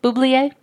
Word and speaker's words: Bublé: Bublé? – Yeah.